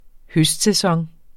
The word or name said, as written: høstsæson